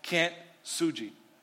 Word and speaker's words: kintsugi: There is this Japanese art called kintsugi.